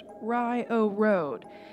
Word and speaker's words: Rio Road: Secondly, Rio Road is pronounced Rye-O Road, even though in actuality it is Route 10. Years ago, sign markers changed Route 10 to a more simplified R10, thus creating the mispronounced confusion.